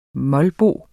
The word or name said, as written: molbo